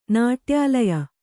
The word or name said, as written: ನಾಟ್ಯಾಲಯ